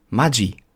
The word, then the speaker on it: Maggi: Das DSCH, das G sozusagen, kommt, wenn ein E oder ein I nach dem G kommt. Maggi. Das ist eigentlich die korrekte Aussprache. Dass es viele hier eingedeutscht haben, ist ja auch in Ordnung. Ich sage, Maggi ist nicht falsch.